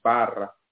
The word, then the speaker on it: Barra: In Spanish, Barra. But Barra, I guess, is the way they pronounce it.